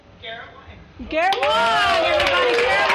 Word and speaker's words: Wang: W A N G is uh, Northern and Central Chinese. W O N G is Southern Chinese.